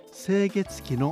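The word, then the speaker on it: 清月記: その中心的な立場にいらしたのが清月期の菅原社長でした。清月期は清い月、それに記録の木と書いて清月期と読みます。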